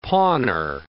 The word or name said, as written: pawnor